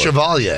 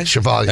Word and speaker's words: Chevalier: Maurice Chevalier.